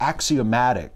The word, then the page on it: axiomatic: American English